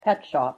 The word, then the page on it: pet shop: English